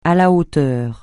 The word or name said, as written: à la hauteur